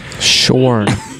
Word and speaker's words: Sean: Spelled the same way as I spell mine. Yes. Which is Shorn. – Shorn.